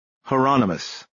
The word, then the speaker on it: Hieronymus: Is that Hieronymus?" Bosch said. "Rhymes with anonymous."